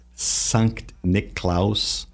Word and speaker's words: Sankt Niklaus: When we think of Sankt Niklaus, the Germanic pronunciation of that, where the origin of Santa Claus comes from as a bishop, why he's dressed in red.